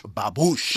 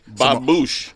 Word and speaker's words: Babouche: Babouche. – Babouche. – Spell that, please. – B-A-B-O-U-C-H. – Babouche. – It's a Moroccan-themed restaurant.